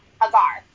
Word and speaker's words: Hagar: Hagar.